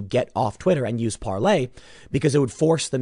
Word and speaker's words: Parler: There's an effort to get people on Parlay, which I use now somewhat. We'll see how it plays out. It's spelled Parler, P-A-R-L-E-R.